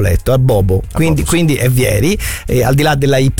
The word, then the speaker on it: Vieri: È vero che c'è Vieri, senza accento sulla I, perché non è francese, no?